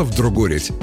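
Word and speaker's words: вдругорядь: Да, именно так, с неочевидным ударением на «о». Обозначало «вдругорить» повторение некоего действия несколько раз.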